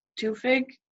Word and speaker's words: Tufik: And I really did like, the name, his name is Sassoon Gabay as Tufik. How do you say it? – Tufik. – Tufik. Okay, sorry.